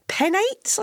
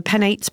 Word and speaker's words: penates: Yeah, well, I'd say penates if I was saying it in Latin, but it could have been anglicised.